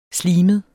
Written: slimet

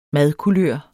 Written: madkulør

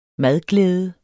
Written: madglæde

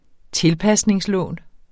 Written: tilpasningslån

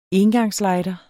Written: engangslighter

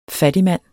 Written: fattigmand

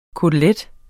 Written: KOTELETTEN

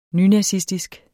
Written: nynazistisk

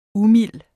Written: umild

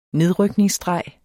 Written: nedrykningsstreg

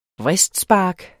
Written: VRISTSPARK

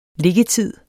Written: liggetid

